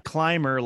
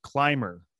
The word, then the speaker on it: Clymer: Today I have Chase Clymer with me. Not Clymer like climbing, but Clymer.